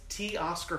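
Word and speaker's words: Teoscar: It's T. Oscar Hernandez. – T. Oscar. – How easy is that? That's literally how you spell it. T. E. Oscar Hernandez.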